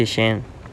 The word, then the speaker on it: Shein: El punto es que si compramos en ropa fast fashion como Shein, o cómo se pronuncia, si Shein, se pronuncia así, no, Shein, sí, Shein.